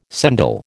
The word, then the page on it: sendal: American English